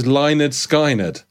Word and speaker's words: Lynyrd Skynyrd: Educate you that the correct pronunciation was Lynyrd Skynyrd.